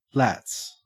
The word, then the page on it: lats: Australian English